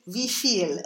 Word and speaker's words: wie viel: If you look it up in a dictionary, it actually says wie viel. And the stress syllable is the second one, right? – Yeah.